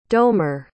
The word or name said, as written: domer